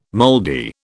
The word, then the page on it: mouldy: British English